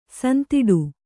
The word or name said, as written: ಸಂದಟಿ